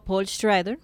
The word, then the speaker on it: Schrader: Eh, Master Gardener, que es de nuestro amigo Paul Schrader.